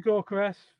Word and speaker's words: Gyökeres: Victor Gyökeres. Victor Gyökeres.